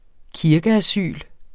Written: kirkeasyl

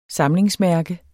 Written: samlingsmærke

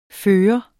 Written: førende

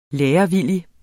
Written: lærevillig

